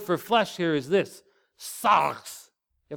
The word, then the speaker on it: sarx: Sarx. The R, you have to kind of roll it, pretend you're a little bit Scottish. Sarx is how you pronounce that.